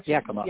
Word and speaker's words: Yakima: July 25th, Yamaka, I don't know how to pronounce that. I think I pronounced Yakima. – It right. Yakima. – Yeah. – Yakima, there you go.